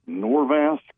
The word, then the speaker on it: Norvasc: So I've got that script still on the potassium, but we added, and I won't try to pronounce it, Norvasc. – Yeah, that's perfect pronunciation.